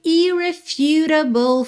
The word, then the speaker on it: irrefutable: Oh my irrefutable father. Irrefutable. Ok? Atenção que esse U tem som de U.